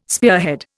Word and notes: spearhead: Indian

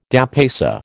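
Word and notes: dépaysé: American English

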